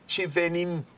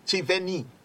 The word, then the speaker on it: Cheyenne: I believe it pronounced Cheyenne, Cheyenne.